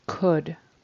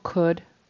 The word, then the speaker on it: could: But that the animal could, I heard cold. It almost sounded like cold, like it's freezing outside, it's cold. So that really needs to be a horseshoe U and no L is pronounced. It's just could.